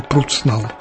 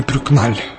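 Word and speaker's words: Прукнал: В чем-то биография Альдоны напоминает судьбу другой польки, известной актрисы театра и кино Анны Пруцнал, если произносить по-польски, или Прюкналь, как она давно известна во Франции.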